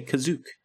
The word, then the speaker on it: Kashyyyk: Like, some people say Kashyyyk, some people say Kazook. – Kazook is so weird to me. It's not spelled like that at all. – Well, I'm sorry. It was first pronounced on screen as Kazook, so...